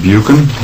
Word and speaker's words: Buchen: My name is Peter Buchan. My last name is spelled B-U-C-H-E-N.